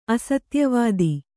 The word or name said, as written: ಅಸತ್ಯವಾದಿ